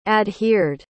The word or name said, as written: adhered